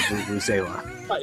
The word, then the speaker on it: vuvuzela: It's a vuvuzela. – Yeah, I knew what it was. I don't know how to pronounce it. – Vuvuzela.